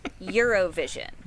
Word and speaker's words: Eurovision: Relax. Four syllables. Eurovision. Yeah.